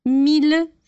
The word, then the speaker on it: mille: No entanto, para as palavras mille, ville e tranquille, a gente vai pronunciar os dois L's com L, normal.